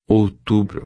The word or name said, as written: outubro